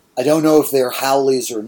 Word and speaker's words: haoles: And the word is not, to my eye at least, pronounced how it is spelled. It is spelled H-A-O-L-E-S, and it is pronounced Howley's.